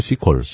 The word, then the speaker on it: SQL: 그 SQL은 보통 이제 SQL을 읽으면 초보라고 그러고 C컬이라고 하죠. C컬. C컬. 예, 다 대부분 C컬이라고 읽습니다.